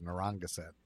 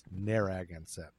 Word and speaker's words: Narragansett: I always thought it was Narragansett, but it's Narragansett, according to YouTube pronunciation.